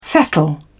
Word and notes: fettle: British English

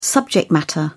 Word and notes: subject matter: British English

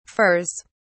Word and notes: furs: British English

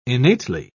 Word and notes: innately: British English

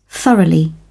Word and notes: thoroughly: British English

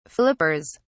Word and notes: flippers: British English

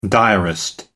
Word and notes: diarist: American English

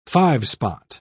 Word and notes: five-spot: British English

